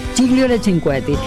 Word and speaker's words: Gigliola Cinquetti: Se escribe claro, se escribe Gigliola, pero es Gigliola Cinquetti. Cinquetti. Así le anunciaban antes cuando estaba en su momento en las radios. Gigliola Cinquetti.